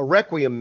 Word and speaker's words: requiem: Requiem mass. – Requiem mass. Okay. A requiem mass.